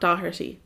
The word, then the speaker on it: Dougherty: But it's not Dougherty. It's spelled Dougherty. – Is it like bread dough.